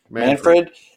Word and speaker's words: Manfred: Manfred's, how you say his name? Manfred? – Manfred.